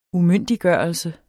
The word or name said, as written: umyndiggørelse